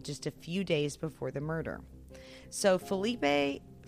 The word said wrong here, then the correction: Philippe: Philip Philippe is actually how you say his name, Philippe.